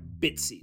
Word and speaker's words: BTSE: It's spelled B T S E. That's right, Bitsy.